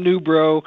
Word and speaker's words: Newbrough: Did I pronounce that right, Newbro? – You were great. Okay. You were so great, Nathan Newbro.